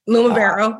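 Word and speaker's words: Lumivero: And I always like to do stuff with, let's see, is it pronounced LumaVero now? – LumaVero, yep.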